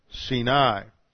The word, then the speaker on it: Sinai: See, Sin is the root of the word that you find in Mount Sinai, how most people pronounce it, but the accent's on the second syllable, and it should be pronounced Sinai.